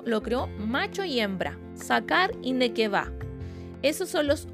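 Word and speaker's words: sacar (זכר): En el original, cuando dice varón, la verdadera palabra que se utiliza, voy a tratar de pronunciarla, es sacar. Se escribe Z-A-K-A-R, sacar.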